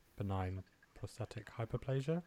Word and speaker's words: benign prostatic hyperplasia: BPH, benign prostatic hyperplasia. I might have said that wrong. – You pronounced that perfectly right.